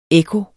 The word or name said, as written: ekko